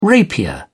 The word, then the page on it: rapier: British English